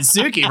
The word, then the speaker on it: Suki: And then Suki rhymes with Dookie.